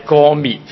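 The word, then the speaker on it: 歌篾: 咁原来何西阿嘅妻子个名呢，咁我上次读错咗嘅，咁应该正確嘅读音呢係歌滅先啱，嗰个係一个滅嘅音，咁所以呢大家可以如果都唔係好識讀呢個字呢，可以喺聖經裏面呢括住返，咁第一呢就唔會讀錯啦。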